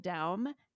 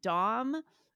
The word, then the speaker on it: Daum: I think I just said Megan Daum and it's caused endless confusion because this is worthy of its own episode, but for years I said Dom, and there's a long, boring backstory to that.